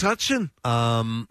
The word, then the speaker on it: Metuchen: Is that how it's pronounced? – Metuchen or Metuchen or something like that. It's Metuchen. In New Jersey.